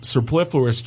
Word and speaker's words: superfluous: Thousands of team have adopted their living documents over email, files, and superfluous chat. Did I say that right? – Superfluous? – Yes. – Yes.